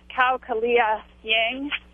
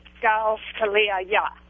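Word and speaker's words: Kao Kalia Yang: The author is, we would pronounce it Cao Kalia Yang, but one of the things I've learned is she would pronounce it Gao Kalia Ya.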